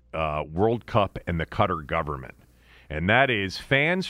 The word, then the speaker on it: Qatar: You know, the World Cup is in November in Qatar, which is, I think, the correct pronunciation of Q A T A R.